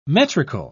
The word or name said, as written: metrical